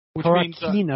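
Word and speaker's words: Carcacinha: Ramos is how do you say his nickname? Do you know how to say his nickname? Carcassina? Carcassina, I believe? – Caracina. – Caracina, that's right.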